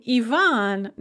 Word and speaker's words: Ivan: But the name Ivan does not. It just starts with an e sound.